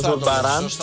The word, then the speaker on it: Ζουρμπαράν: Δεύτερο μαγαζί είναι το Ζουρμπαράν, αν το προφέρω σωστά, αν τονίζω καλά μάλλον. – Σωστά, το Ζουρμπαράν.